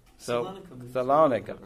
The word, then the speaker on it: Salonika: Shalunki? How do you pronounce that? Someone. – Salonika.